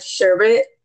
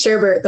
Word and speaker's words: sherbet: And before I read this post, I had to look up the pronunciation of sherbet. I thought it was sherbert the whole time.